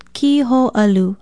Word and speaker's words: kiho'alu: Be sure to put in the kahako or makron over the I and O and pronounce it kiho'alu.